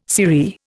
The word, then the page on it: sirree: Indian